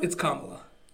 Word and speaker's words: Kamala: I mean, you pronounce Kamala Harris's name wrong. But is it Kamala? – It's Kamala.